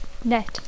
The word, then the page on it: net: English